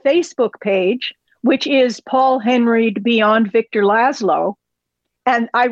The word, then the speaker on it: Henreid: Again, it's We Love Paul, H-E-N-R-E-I-D. Follow her. – I think both. – He pronounced it Henreid, didn't he? – It's Henreid. He's doing fine.